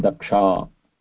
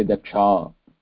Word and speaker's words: Daksha: I should pronounce my name Daksha, right? – Yeah, you should pronounce it Daksha. – Daksha. – What is your name? You should say Daksha. – Daksha. So no visarga, right? – No visarga. It's like Lata, Rama.